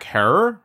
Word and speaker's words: Herer: Jack Herer, rhymes with terror.